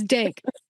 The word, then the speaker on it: Denk: She is a mixing and master engineer, music producer, and proud owner of, I'm going to say this, your studio's name wrong, Dinky Studios. Did I say it right? No? – That's great. It's Dank. D-E-N-K, okay, Dank Studios.